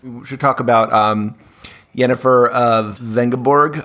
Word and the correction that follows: Vengerberg: I guess it is. Hopefully I'm pronouncing that correctly. – Vengerberg.